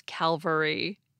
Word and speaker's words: cavalry: I kept mispronouncing it as cavalry. The word for troops who ride horses is cavalry.